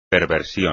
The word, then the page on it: perversión: espagnol